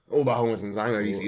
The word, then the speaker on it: Oberhausen: Oberhausen. Ja. Das ist Betonung auf Ober und nicht Oberhausen.